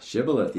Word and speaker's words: Shibboleth: I think you said Shibboleth. – Shibboleth, yeah.